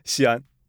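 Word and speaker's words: Xi'an: So we are back with, and I'm going to mispronounce it again, Xian. – Xian.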